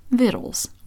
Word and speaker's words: victuals: book, Vittles, which is spelled V-I-C-T-U-A-L-S.